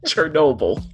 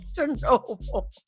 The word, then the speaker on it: Chernobyl: Chernobyl. – Chernobyl.